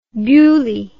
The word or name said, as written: beaulieu